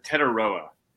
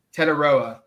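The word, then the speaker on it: Tetairoa: We're waiting on not only Ben Roberts' decision this week, but also that of receiver Tatario McMillan. I probably butchered his first name. I've still not been able to pronounce it correctly. – Tetaroa. – Tetaroa, that's correct.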